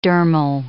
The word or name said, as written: dermal